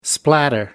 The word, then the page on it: splatter: American English